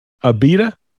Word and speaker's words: Abita: I'm assuming that's how you pronounce it. – Abita. – Is it Abita? – Abita. – Abita? – Yeah, Abita.